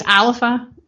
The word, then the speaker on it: Alba: It's spelled A-L-B-A, so a lot of people think it's pronounced Alba, but it's Alpha in Gaelic.